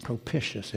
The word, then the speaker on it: propitious: Let me spell that for you, because it sounds as if it ought to be pronounced differently. It's spelled P-R-O-P-I-T-I-O-U-S. Propitious. It's pronounced propitious. It looks like it ought to be pronounced propitious, doesn't it? But it's technically, from what I understand, pronounced propitious. And what does it mean?